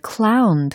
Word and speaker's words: clown: 어, 나는 어디에서든 가수나 or the clown 들으셨죠? C-L-O-W-N. 광대라는 의미입니다. 어, 가수나 광대가 될 수 있어요. 라는 거죠. 어, 참고로 crown이라고 하면 왕관, crown이 되는 거고 clown이라고 발음이 되었잖아요.